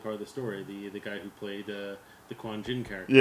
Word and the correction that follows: Qui-Gon Jinn: If I'm saying the name right, because I feel like I'm... – Qui-Gon Jinn. – Qui-Gon Jinn. I think Quan Jinn was Mortal Kombat, maybe.